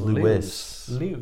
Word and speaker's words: Lewes: Lewis. That's how they pronounce it, yeah. – Do they now? – Yeah. – Oh, Lewes.